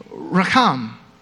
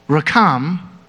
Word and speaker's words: racham: And the Hebrew word for compassion is racham. In Hebrew, they're the exact same spelling, but they only vary in pronunciation. Rechem, womb, racham, compassion.